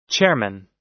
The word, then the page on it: chairman: American English